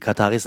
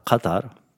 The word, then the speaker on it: Katar: Also die Kataris sagen Katar und Katari mit so einem Kaf. In England, Amerika sagt man wirklich Katar, Katar.